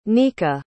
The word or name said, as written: nika